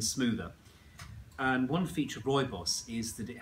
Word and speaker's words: rooibos: So this is rooibos or red bush or bush tea or red bush tea. People pronounce rooibos lots of different ways, but that's how I say it.